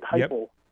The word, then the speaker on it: Heupel: I think you've got I said that right, Heupel.